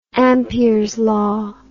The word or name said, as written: Ampere's law